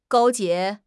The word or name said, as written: 勾结